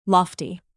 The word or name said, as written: lofty